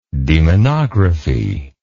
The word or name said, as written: demonography